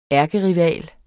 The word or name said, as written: ærkerival